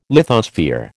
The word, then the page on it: lithosphere: American English